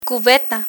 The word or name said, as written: cubeta